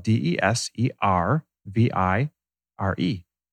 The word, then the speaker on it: deservire: Now, the Latin root of the word deserve comes from the Latin word "deservire," It's spelled D E S E R V I R E, "deservire."